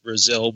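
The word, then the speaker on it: Brazel: And it is pronounced Brazil because I met some of his relatives only last weekend, and I had always called him Mac Brazil. And they said, no, it's Mac Brazil.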